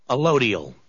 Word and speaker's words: allodial: Allodial.